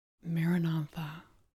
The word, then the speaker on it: Maranatha: Then silently say the word, Maranatha. Recite it as four syllables of equal length. Mar, Ra, Na, Tha.